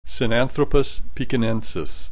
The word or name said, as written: Sinanthropus Pekinensis